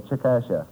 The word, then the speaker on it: Chickasha: C-H-I-C-K-A-S-H-A. I said Chickasha. – Oh, Mr. McNeil. – That is not right, I've taken. No, no. But the name is pronounced Chickasha.